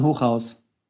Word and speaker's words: Hochhaus: Not at all, but it's German. So in Germany, you would say Stefan Hochhaus.